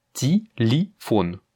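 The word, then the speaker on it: téléphone (телефон): On a deux yé qui ne sont pas accentués, l'accent tonique étant sur le O, comme tu le vois. Et donc ce mot, étant donné que les yé ne sont pas accentués, ils vont se prononcer comme des i, ce qui va donc donner téléphone, ok?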